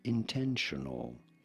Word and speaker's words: intentional: Intentional. You do want to stress on the second syllable, the ten syllable.